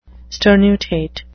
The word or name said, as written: sternutate